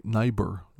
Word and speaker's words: Niebuhr: Reinhold Niebuhr said, that's not how you say his name, Niebuhr, said, forgiveness is the final form of love.